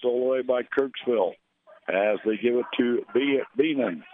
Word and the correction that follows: Bannon: Bannon, that's the way you pronounce it.